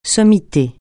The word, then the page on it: sommité: francais